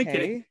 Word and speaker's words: Blinken: Blinken. Come on, man. Come on, Abby. I got Blinken. Blinken. You know what Blinken rhymes with? It rhymes with Lincoln.